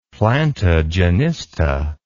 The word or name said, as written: plantagenista